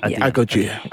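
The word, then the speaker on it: Agojie: So they have that moment when they were in when Naoi first kind of joins the how do you pronounce Agogia. – It? Agogia. Agogia. It's Ia, isn't it? – Yeah. Agogia.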